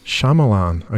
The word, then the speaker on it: Shyamalan: Night Shyamalan. Shyamalan.